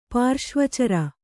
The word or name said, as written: ಪಾರ್ಶ್ವಚರ